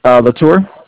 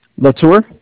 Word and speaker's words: Latour: Latour. – Huh? – Latour. – Notour? How do you spell that? – L-A-T-O-U-R.